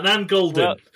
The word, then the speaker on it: Nan Goldin: Nan Goldin, I think. Let me just check that I've said that right, because I've completely made that up. Yeah, I was right. I feel good about that. Nan Goldin.